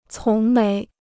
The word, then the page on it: 从没: cóng méi